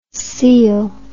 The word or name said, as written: cele